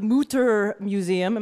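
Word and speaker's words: Mütter Museum: Where you work in one of my favorite places, the Mutter Museum. Am I saying it right? – You are.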